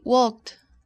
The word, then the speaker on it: walked: Notice the ending of the verb. K, k. el sonido con aire en la garganta pero sin cuerdas vocales. K, k. Así que, ¿cómo se pronuncia to walk en el tiempo pasado? – In the past tense, you would say walked, walked. – Correct. Walked. Walked. Es como así, hay una T al fin en lugar de ed.